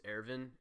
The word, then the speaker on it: Erwin: Because in the English dub it's Ervin, and I'm the dub watcher, I guess, but oh yeah, I watch it sub and it's just Erwin. – Yeah, I mean, it's spelled the same way, but yeah, they say Ervin.